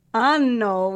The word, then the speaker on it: anno: I was not pronouncing my double consonants, and in Venice, one friend said, "make sure you prolong the N in anno." Because you're not. It's not a good thing. – Just to clarify for Katie and anyone who's listening, anno with a double N is year, and anno with a single N is anus.